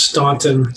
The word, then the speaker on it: Staunton: Like Staunton? – Stanford? Yeah. – Yeah. – Oh, okay. Yes. So it is officially pronounced Staunton.